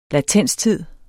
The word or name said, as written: latenstid